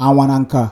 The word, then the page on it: Anwananka: LLLL